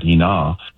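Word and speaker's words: ina (ἵνα): in order that, ina, is the Greek pronunciation there, in order that they will be destroyed.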